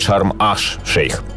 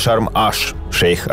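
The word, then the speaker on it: Шарм-эш-Шейх: Вот, э, например, возможно, кто-то не знал, что даже само название Шарм-эль-Шейх на самом деле не Шарм-эль-Шейх, а правильно этот город называют Шарм-Аш-Шейх, то есть залив Шейха или, как вариант, Королевский залив. Вот, так что правильно Шарм-Аш-Шейх, а не Эль.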